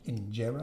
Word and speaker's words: injera: Do you pronounce it injera? – Injera, yes.